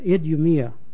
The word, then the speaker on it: Idumea: The Romans called him Idumea. They pronounced that Idumea.